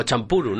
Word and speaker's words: Champloo: O Champuru, ¿no? Es Champuru. – ¿Sabes Champuru? – Champuru. Champuru. – Champuru será como lo pronuncian en Japón.